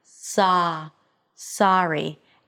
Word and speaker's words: sorry: But it is not a pure O as many non-native speakers pronounce it. It's not sorry, it's sa, sorry.